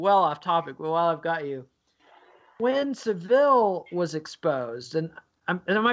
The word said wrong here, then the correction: Savile: I am I still pronouncing that wrong? Is that you're still pronouncing Sav- it wrong? Savile. Savile.